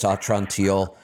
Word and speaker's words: Atrantil: Type in Atrantil. Just type in A-T-R-A and it'll come up. There's nothing else in the store spelled like that. So A-T-R-A, it'll come up. It's Atrantil.